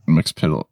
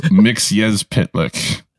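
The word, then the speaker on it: Mxyzptlk: also just for continuity in Supergirl in the Arrowverse, it's pronounced, where is it? Mix-a-pitalic. – Mix-a-pit. Isn't that what it was pronounced in this episode? – No, it was that was Mix Yez Pitlick.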